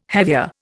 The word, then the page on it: hevea: Indian